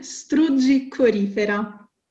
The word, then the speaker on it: strugicorifera: La seconda parola oh, rientra sempre nella nostra rubrica non si dice più così perché, ed è strugicorifera con l'accento sulla I. La ripeto, strugicorifera. – Strugi corifica. – Corifera. – Oh, corifera. – Esatto, perfetto.